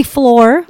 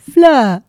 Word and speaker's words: Fleur: The vein of my life is that nobody in this country can pronounce my name. – I think they call you Floor. – They call me Floor. They call me Peler, Fleur. Everything except Fleur, which in England is a name, it's a Harry Potter character, but here it's a complete and utter nightmare.